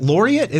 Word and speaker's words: laureate: All right, I just want to make sure I wasn't saying it's spelled like lariat, but it's laureate.